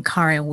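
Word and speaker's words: Karin: I am Karen Weary, your host.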